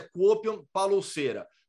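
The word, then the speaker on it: Kuopion Palloseura: E nem sei se essa é a pronúncia correta, mas eu sei que o nome completo é Kuopion Palouceira. Como falam na Finlândia? Exatamente assim.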